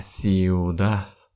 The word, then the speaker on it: ciudad: Обратите внимание, в этих словах последняя буква «D», но мы не произносим ее как букву «D». В предыдущем уроке, когда мы с вами проходили словарь, я вам рассказал про то, что если буква «D» стоит в конце слова, то в классическом испанском произношении она не будет звучать как русская буква «D». Она звучит как звук «С». «Ciudad», «Universidad», «Libertad».